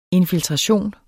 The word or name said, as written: INFILTRATIONER